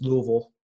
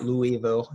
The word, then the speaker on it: Louisville: And it is two syllables. It's Louisville, not Louisville.